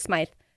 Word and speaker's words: Smyth: Like, the fact that you know their name or like, my last name is S-M-Y-T-H, which people pronounce Smith. They pronounce Smythe. I pronounce it Smythe.